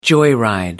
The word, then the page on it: joyride: British English